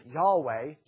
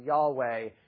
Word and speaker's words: Yahweh: And then again later on, Exodus 6:3 and other sections again make reference to this name, which we would pronounce it Yahweh, typically, though actually we don't really know how the Hebrews pronounce it.